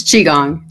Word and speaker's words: Qigong: She has an eclectic background in project management, leadership development, improv comedy, and storytelling, along with, what is this, Qigong? Do I pronounce that right? – It's Qigong. Qigong. – Qigong. Okay, there you go.